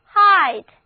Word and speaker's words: height: 再来看这个单词 h-e-i-g-h-t， height， e-i 字母组合发 i 的音，而字母组合 g-h 不发音。